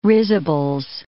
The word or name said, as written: risibles